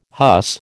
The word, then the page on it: huss: American English